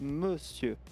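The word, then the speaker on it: Môsieur: Alors c'est Monsieur. – Monsieur, parce que... Oui. Mais il s'écrit d'une façon particulière. – Voilà, comme Monsieur, mais sans le N. Et avec un circonflexe sur le O.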